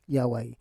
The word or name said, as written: yawai